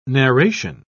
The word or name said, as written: narration